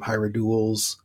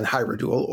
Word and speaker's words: Hierodule: So, however, based on that, I think it should be Hierodule, rather than Hyrodule or Hyrogiles, based on the original pronunciation.